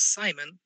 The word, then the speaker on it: Simen: My name is Simon, if that's what you're asking.